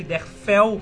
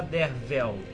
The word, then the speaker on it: Derfel: Então ele Dervel se escreve com F, Derfel, mas se fala Dervel.